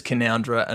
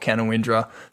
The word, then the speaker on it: Canowindra: It's Canowindra and not Canowindra.